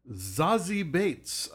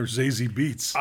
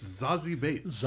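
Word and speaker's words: Zazie Beetz: Played by Zazie Bates, I just found out is how you say her name. – I've been calling her Zazie Beats. – I have too, but it turns out she's like German or something, and it's Zazie Bates.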